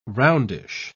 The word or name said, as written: roundish